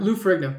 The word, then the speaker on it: Lou Ferrigno: Lou Ferrigno.